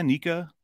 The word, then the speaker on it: NECA: Is it NECA? NECA? – I pronounce it NECA.